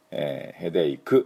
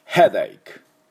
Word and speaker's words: Headache: で発音ですけども H の音はっはっはね、で、あとは Ache 持ち上げる時の K の音ですね、 Headache って言わないように。で私の後について発音いきましょう。 Headache Headache、はい、いいと思います。